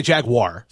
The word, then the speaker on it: Jaguar: I say Jaguar. – So do I. – But I notice a lot of people will say Jaguars. – Infidels. – Terrible. That's not how you pronounce that word.